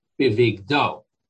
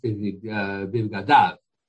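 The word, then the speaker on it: bivgadav (בגדיו): It's written bivigdo, but it's pronounced bivgadav, his clothes.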